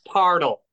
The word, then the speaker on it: Parnell: It's pronounced Pardle, Manus.